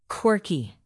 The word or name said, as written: quirky